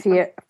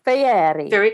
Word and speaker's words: Fieri: Guy Ferrari? Is that how you say his name? Fieri. Fieri. Guy Fieri.